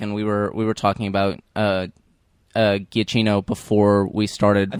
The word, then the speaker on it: Giacchino: It's Wikipedia says Giacchino is how it's pronounced.